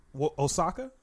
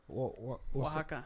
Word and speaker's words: Oaxaca: Osaka? – Oaxaca.